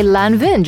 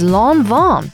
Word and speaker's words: Lanvin: This next one is very surprising for me personally, because before I used to say Lanvin just the way it's written. This brand, it's written L-A-N-V-I-N, but actually, the way you pronounce it is Lanvin, Lanvin, not Lanvin.